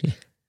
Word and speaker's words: Orlovsky: I feel as though that is some of what's appealing to people when Orlovsky, is it or- Orlovsky? Am I pronouncing yeah, that correct? Orlovsky